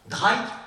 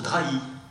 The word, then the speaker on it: Drahi: Ça se prononce Dray ou ça se prononce Dray ? Et lui disait, ni l'un ni l'autre. Ça se prononce Drahi. Deux syllabes.